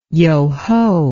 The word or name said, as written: yoho